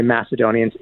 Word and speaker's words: Macedonians: Sorry, I, I keep forgetting to say Macedonians. It's a kappa, so I'm using it uh, as a hard K instead of a soft C.